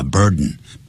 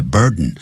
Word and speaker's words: burden: B-U-R-D-E-N, a burden, B-U-R-D-E-N, the burden. You should assume the burden, 不要念成 burden, the burden, 用鼻音 student, 学生、I'm a student, 不要念 I'm a student, 不要这样念。快的时候外国人他们就会念成 student, burden. Assume